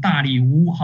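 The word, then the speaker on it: 大利乌: 哈该书的背景呢，我们刚刚讲到说是在波斯王大力乌哈的时候，大力乌呢又称大流士。那个大力乌呢，是我们根据亚兰文的原文发音发出来的哈。大流士呢是根据希腊文的发音来说出来的。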